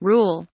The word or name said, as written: rule